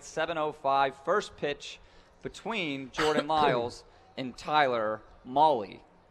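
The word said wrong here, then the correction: Mahle: who I believe I've been pronouncing wrong. Mally. They even wrote it in the thing. Mally like Rally.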